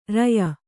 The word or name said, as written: ರೂಯಿ